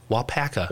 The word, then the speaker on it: Waupaca: Wapoka. How do you say it? – Wapaka. – Wapaka. – We talked about this last night. Alpaca. Wapak. – Alpaca. – Alpaca, the animal.